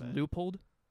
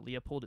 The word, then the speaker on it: Leupold: It's actually pronounced Leupold, but I always pronounce it Leopold. It's spelled like L-E-U-P-O-L-D.